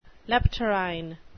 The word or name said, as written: leptorrhine